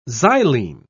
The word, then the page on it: xylene: British English